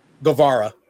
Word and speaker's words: Guevara: How do you say his name? – Guevara.